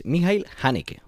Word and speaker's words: Michael Haneke: Sino que, buscando un poquito por internet, pues uno puede localizar cómo se dice, y es Michael Haneke. O sea, en cualquier caso, el apellido de él sería Haneke.